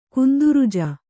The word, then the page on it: ಗಂಧರಾಜ: gandha rāja